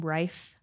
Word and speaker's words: Rife: Rife? – Yeah, R-I-F-E.